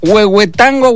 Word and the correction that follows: Huehuetenango: Guatemala. No es Huehuetenango, Guatemala. Así es, Huehuetenango, Guatemala.